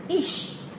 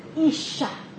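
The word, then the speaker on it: ish (אִישׁ): 男人希伯来文的男人的发音是 ish，而其他那个女人的名字希伯来文的发音是 isha，所以男人是 ish，他叫女人的名字叫 isha，是不是很相连啊？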